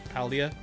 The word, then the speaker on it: Paldea: I think it was like leaked said it was something else, but it was very close to what it's pronounced as, Paldea.